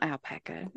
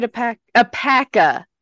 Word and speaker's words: Apaka: I highly recommend Googling Alfred Apaka, because he's not Alpaca. I said that wrong, didn't I? No, that's it. Alfred Apaka.